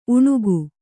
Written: ಉಣಿಕ

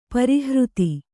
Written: ಪರಿಹೃತಿ

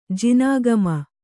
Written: ಜಿನಾಗಮ

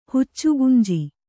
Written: ಹುಚ್ಚುಗುಂಜಿ

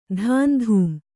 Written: ಧಾಂಧೂಂ

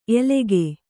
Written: ಎಲಗೇ

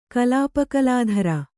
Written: ಕಲಾಪಕಲಾಧರ

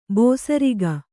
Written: ಬೇಸರಿಕೆ

